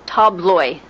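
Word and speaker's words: tabloj: All the plurals end with a letter which looks like a J, but which is pronounced like a Y. So, for example, tablo, table, tabloi, tables.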